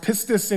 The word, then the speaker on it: pistis: And so faith is this, it's this Greek word, all those Greek letters, and then it pronounced pistis in Greek, but it's basically, it's a belief, a trust, or a confidence.